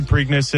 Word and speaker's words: Preakness: The next question, I'm not sure how to pronounce this. The Preakness? Preakness? – The Preakness. Yeah, Preakness.